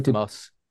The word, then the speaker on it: Lanthimos: So the latest film, I'm not going to, from Lanthimos, is it that how you pronounce his name? Lanthimos? – The guy Lanthimos. Yorgos Lanthimos.